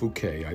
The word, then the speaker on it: Phuket: A huge influx of Russians in Phuket is, uh, uh, uh it's pronounced Phuket, I believe. A lot of people pronounce it something else.